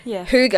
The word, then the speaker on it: hygge: Hygge, H-Y-G-G-E. I don't know why it's pronounced that, but Hygge sort of says, you know, just live comfortably, be comfortable, make everything warm and comfy.